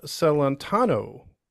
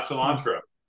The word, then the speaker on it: Celentano: The Lone Star Halo coming in from Texas, and we're talking to Mr. Jeff Celentano, if I said that right. – Yes, sir. You did. Not cilantro.